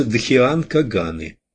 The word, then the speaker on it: Дхьянкаганы: Дхьянкаганы и так далее. Чаще всего в тайной доктрине космические разумы различных степеней именуются Дхьянкаганы, ударение на втором слоге.